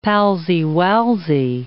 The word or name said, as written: palsy-walsy